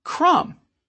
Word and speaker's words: crumb: And what about the silent B in crumb? You get a crumb when you crumble a cookie.